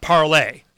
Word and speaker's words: Parler: It's pronounced parlay. It's like, it kind of sounds piratey. Little bit of parlay.